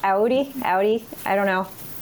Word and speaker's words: Audi: And Audi is how I pronounce Audi. If okay. – that is wrong, please let me know.